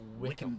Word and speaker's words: Wycombe: But it's spelled like Wycombe. – Wow. – So Wycombe. – Yeah. – But it's pronounced Wickham.